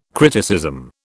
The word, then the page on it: criticism: British English